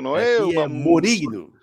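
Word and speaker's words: Morínigo: E Ariel, falando do jogo de hoje, o como que se pronuncia? É Morigno mesmo que pronuncia, ou Ariel, tem, ou temos alguma variação aí no sobrenome do nosso técnico? Cara, eu não consigo entender por que o problema de vocês para pronunciar, porque aqui é. – Como é, fala? Claro, é Morinigo.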